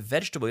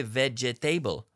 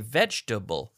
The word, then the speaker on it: vegetable: For example, vegetable, verdura, vegetable. We say vegetable, we don't say vegetable. We say vegetable, three syllables, da da da.